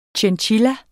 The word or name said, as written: chinchilla